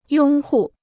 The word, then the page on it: 拥护: yōng hù